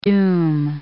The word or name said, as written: doom